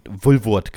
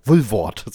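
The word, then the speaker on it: Woolworth: In Bremen heißt der Laden übrigens einfach Wulwort genannt. Wulwort. So richtig schön wir sind Deutsche, wir können sie aussprechen.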